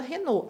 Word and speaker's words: Renaux: Eu aqui, Bem Vida Real, tenho um nome difícil, um nome de origem francesa, que tem até uma pronúncia difícil. Então, Camila Renault, a gente fala de um jeito diferente do que escreve.